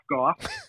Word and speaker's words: Gough: Also, another way to remember my name is No Cough Gough.